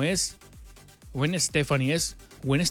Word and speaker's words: Gwen: A ver, Gwen Stephanie se pronuncia Gwen... Para G-Wen.